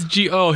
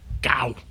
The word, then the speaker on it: Gou: See, that's G-O. His name is spelled G-O-U. – Gow. Gow. Gow. – But I think it's pronounced go, as far as I can tell.